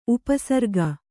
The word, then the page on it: ಉಪಸರ್ಗ: upa sarga